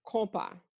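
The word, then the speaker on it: konpa: So it's K-O-N-P-A because of the vowel sound of the O-N, on, uh-huh. – has the compa.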